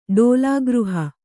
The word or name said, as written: ಡೋಲಾಗೃಹ